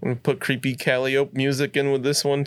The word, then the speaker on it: Calliope: I think it's pronounced Calliope. – Creepy Calliope. – That sounds way more correct than what I said.